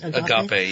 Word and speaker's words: agape: Agape. Yes, it's Greek.